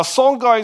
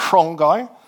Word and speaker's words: Songhai: Now, Songhai is how we say in English. Some people think that should be pronounced Songhai, but we'll just keep it pronounced. We'll keep the pronunciation simple.